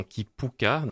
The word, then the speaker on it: kipuka: Donc c'est un terme de volcanologie hawaïen, un terme hawaïen donc, qui se prononce effectivement Kipuka, le U se prononce U.